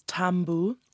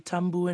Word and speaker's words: tabu: We have an invisible M sound, so it's tambu. So it's a similar word, tapu in Maori, tambu in Fijian.